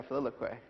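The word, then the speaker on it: filioque: philoquy. Am I saying that right? Yeah, philoquy, yes.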